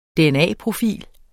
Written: dna-profil

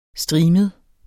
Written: STRIMET

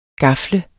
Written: gafle

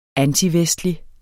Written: antivestlig

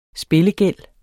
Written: spillegæld

